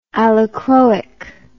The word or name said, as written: allochroic